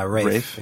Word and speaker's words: Ralph: Yeah, Rafe.